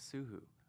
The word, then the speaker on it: SooHoo: Yeah, my last name is SooHoo.